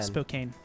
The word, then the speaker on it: Spokane: Spokane? – I think it's Spokane. – Spokane.